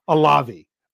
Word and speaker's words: Olave: You've got Chris Olave. I'm pretty sure I'm saying that right. People told me how I needed to pronounce it.